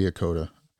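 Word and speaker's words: Echota: And I'm going to butcher the name of it, but it's the Treaty of New, and it's E C H O T A. – Akota? – Akota, or yes, it would be Akota.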